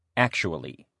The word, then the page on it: actually: male